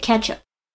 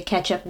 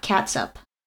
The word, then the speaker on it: ketchup: But the interesting thing is she actually pronounces it ketchup. – Hmm. – At least still spells it like that. – Yeah. At least I think I heard her say ketchup, not catsup.